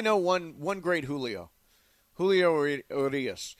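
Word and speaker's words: Urías: And by the way, don't sleep on World Series hero Julio Urias or Dustin May in that it's rotation. It's not Urias, it's Urias.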